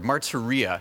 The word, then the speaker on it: martyria: The Greek word translated here is martyria, or martyria, actually. The emphasis is on the last part of the word, martyria.